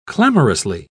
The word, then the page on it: clamorously: British English